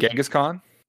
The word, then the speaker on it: Genghis Khan: Genghis Khan.